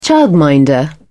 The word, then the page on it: childminder: British English